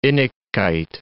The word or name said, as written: Innigkeit